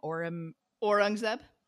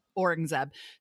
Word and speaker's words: Aurangzeb: So I think talking about, tell me again how to say the king's name, Aurangzeb. – Aurangzeb.